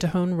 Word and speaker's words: Tejon: Tejon, T-E-J-O-N Ranch. Tejon Ranch.